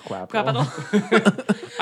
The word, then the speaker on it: Quapital: That's it's a bad capital with a Q. – No. Yeah. – It's pronounced Quapital.